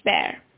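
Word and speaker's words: Bhaer: Another difference that I noticed was about the fight between Jo and Professor Friedrich Bear. I don't know whether I pronounced Professor's name correctly. What I know is that his name is pronounced something in between the words Bear and Beer.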